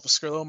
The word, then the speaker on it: Pescrilo: You can find me on Twitter at Paul Pescrillo. My last name is spelled P-E-S-C-R-I-L-O.